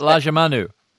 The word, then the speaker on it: Lajamanu: The other one was called Lamangiu. I'm always finding it hard to actually pronounce. – Lajamanu. – That's it. You got it.